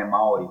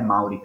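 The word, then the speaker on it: Māori: ma è Maori perché c'è il Macron, ovvero quella lineatina orizzontale sopra là, e quindi la pronuncia corretta di questo popolo tradotto in italiano è appunto Maori.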